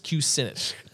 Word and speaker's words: Kucinich: Dennis Kucinich.